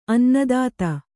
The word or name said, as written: ಅನ್ನದಾತ